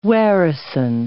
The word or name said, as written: warison